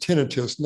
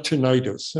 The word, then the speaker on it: tinnitus: And by the way, the word is pronounced tinnitus, not tinnitus.